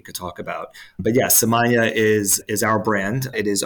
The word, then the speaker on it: Samanea: And by the way, for those keeping score, it's spelled Samanea, and it looks like it should be Samanea, but it actually rhymes with lasagna.